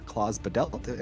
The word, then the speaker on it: Klaus Badelt: Composed by Klaus Badelt.